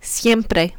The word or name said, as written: siempre